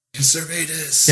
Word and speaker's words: Servetus: Calvin killed Servetus. Did I say his name right? – Servetus.